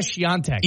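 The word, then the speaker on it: Świątek: That's Iga Swiatek. – Iga Swiatek? Okay. Swiatek. Iva Jovic? – Okay.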